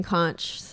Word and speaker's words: conch: There's two ways of pronouncing it. There's conch and conch.